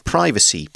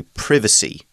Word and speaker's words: privacy: We're being more specific and talking talking about a privacy policy or you could say privacy policy. Privacy, privacy are both okay pronunciations.